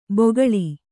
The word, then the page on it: ಬೊಗಳಿ: bogaḷi